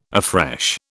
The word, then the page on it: afresh: British English